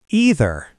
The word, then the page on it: either: American English